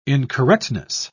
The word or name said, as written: incorrectness